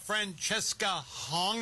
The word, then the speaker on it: Francesca Hong: Well, Francesca Hong, is that how you pronounce her name? Mm-hmm.